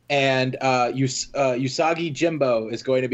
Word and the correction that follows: Yojimbo: The Yojimbo. Sorry, I always mispronounce that.